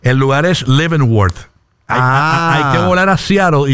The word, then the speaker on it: Leavenworth: Leavenworth. – Qué bien lo pronuncias. – Leavenworth.